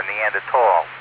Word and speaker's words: Neanderthal: We're all a little Neanderthal, or Neanderthal, if you want to pronounce it correctly.